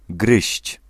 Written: bite

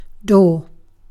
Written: door

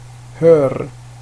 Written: hör

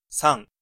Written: trois

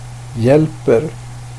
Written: hjälper

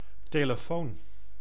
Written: telefoon

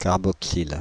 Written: carboxyle

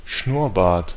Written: Schnurrbart

